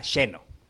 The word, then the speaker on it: Xeno: se llama Sheno. Y así se pronuncia, Sheno.